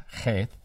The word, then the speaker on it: Cheth: In the 6th section, which is called Cheth, it's spelled H-E-T-H, Sandra, but it's pronounced Cheth.